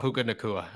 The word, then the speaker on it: Puka Nacua: who again I can't even pronounce his name, but P- Puka Thank you, um, Puka Nacua.